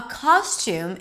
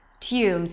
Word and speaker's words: costume: So a costume is pronounced with a k sound, and then it's the ah as in father, ka, and then there is an s, kos, kos. The second syllable is tum, a t sound, the oo as in food, and an m, tum. You can also say tume with a y sound right before the oo, tume.